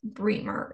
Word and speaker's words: Bremer: So we decided to do, I want to keep saying Brian Bremer, but I really think it's Bremer is how you pronounce it.